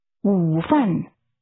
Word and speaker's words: 午饭: 吃午饭。饭。午。午饭。午饭。Lunch is pronounced with a falling, rising tone, followed by a falling tone. How do you say lunch? – 午饭。